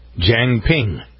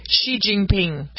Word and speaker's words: Xi Jinping: It's spelled X-I-J-I-N-P-I-N-G. – Jiang Ping.